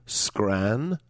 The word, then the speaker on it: Scranton: It's not a difficult word. It's got two syllables. Scranton. It's not like prestidigitation. It's not like anti-disestablishmentarianism. It's two syllables.